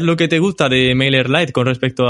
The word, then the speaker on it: MailerLite: Y entonces me cambié a MailerLite y genial. – Pues esa no sé cuál es, ¿cómo se dice? – MailerLite. – Sí. Ah, MailerLite.